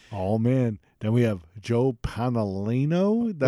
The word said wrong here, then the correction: Joe Pantoliano: I think he butchered that pretty bad. That's okay. It's Joe Pantoliano.